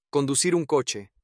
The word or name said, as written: conducir un coche